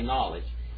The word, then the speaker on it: knowledge: Gnosko, we don't pronounce the K, do we? So we say knowledge.